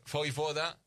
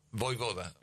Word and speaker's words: Vojvoda: Vojvoda. – Vojvoda. – Vojvoda. Vojvoda. – Que no... – Sí, porque la J se pronuncia ahí.